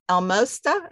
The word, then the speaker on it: Almosta: Give us a little flavor about Al- Almosta. Is that how you say it? Almosta Farm? – Yeah, kind of like it's almost almost farm, but not, almost the farm.